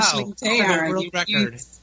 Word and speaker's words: Aisling Teillard: How do I say your name properly? – You got it surprisingly accurate, which has to be a first for my name. Yeah, it's wow. – Aisling Teillard.